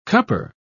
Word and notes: cupper: British English

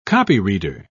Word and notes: copyreader: British English

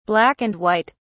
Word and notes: black and white: British English

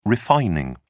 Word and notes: refining: British English

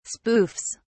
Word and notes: spoofs: British English

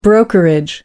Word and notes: brokerage: American English

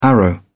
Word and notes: arrow: British English

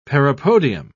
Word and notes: parapodium: British English

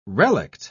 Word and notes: relict: British English

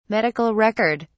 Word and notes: medical record: British English

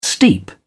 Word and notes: steep: British English

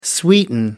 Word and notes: sweeten: American English

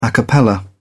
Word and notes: a cappella: British English

